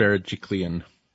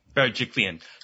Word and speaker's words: Berejiklian: Bergicani. Oh, how do you pronounce her last name? – Berejiklian. – Berejiklian.